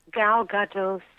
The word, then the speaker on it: Gadot: But everyone says that Gal Gadot steals the show. – Yes, that's... – If that's how you pronounce her name. – Yep, that is our opinion, too, around these...